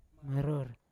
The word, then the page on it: maror: Yawa